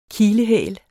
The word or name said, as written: kilehæl